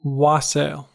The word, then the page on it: wassail: American English